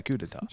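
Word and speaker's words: coup d'état: It's supposed to be pronounced coup d'etat, right? – Well, a coup d'etat? – Well, if you shorten it, coup's fine, but yeah, coup d'etat.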